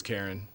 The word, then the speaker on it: Caryn: One influencer created an AI bot of herself named Karen, but it's spelled C-A-R-Y-N. – Impressive. – Could be Karen then, couldn't it? – Karen. – No, I think it's Karen.